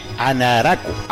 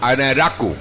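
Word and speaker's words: Anraku: Y él mismo dijo cómo se pronunciaba su apellido, ¿verdad? – Sí. – Anaraku. – Anaraku.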